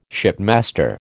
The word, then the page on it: shipmaster: American English